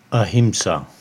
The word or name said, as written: Ahimsa